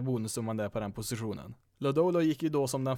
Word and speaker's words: Lodolo: With the seventh selection of the 2019 MLB draft the Cincinnati Reds select Nick Lodello, a left-handed pitcher from Texas Christian University. – Ja, nu tror jag kommissionären fick lite fel uttal där på Cincinnati Reds första val där. Nick Lodolo, nummer sju totalt i den här draften.